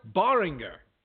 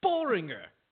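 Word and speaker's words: Böhringer: And who the heck is Moritz Boehringer? Totally biffed that name. – Yeah, you screwed up the pronunciation already. – Boehringer. Boehringer. – That's better.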